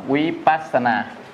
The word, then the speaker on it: Vipassana: À, thiền Vipassana tiếng Pali đọc là Vipassana. À, Vipassana.